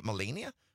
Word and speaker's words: Melania: Melania. Is that how you pronounce it? Melania? – Melania.